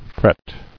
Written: Fretting

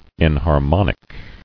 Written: Enharmonic

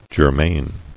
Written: Germane

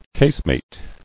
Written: Casemated

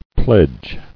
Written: Pledging

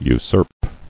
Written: Usurp

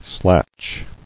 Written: Slatch

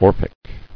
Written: Orphic

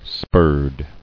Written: Spurred

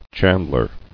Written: Chandlery

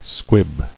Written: Squibbed

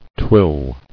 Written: Twilling

